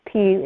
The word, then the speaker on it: Pugh: My last name is Pugh.